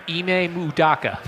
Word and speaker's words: Ime Udoka: Ime Udoka. – Nailed it. Where does the M come in? – Ime. – Right. You just said Mudoka. – Ime Udoka.